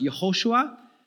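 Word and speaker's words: Yehoshua (יהושע): So in Hebrew, the name Joshua is pronounced Yehoshua.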